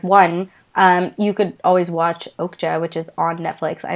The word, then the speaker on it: Okja: I think you're pronouncing it right. – Okay, I heard somebody in like the Korean film cinema world say it as Okja the other day,